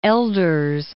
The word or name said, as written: Elders